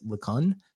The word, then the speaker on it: LeCun: So his AI guru is, I think it's LeCun is how it's pronounced.